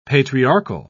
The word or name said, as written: patriarchal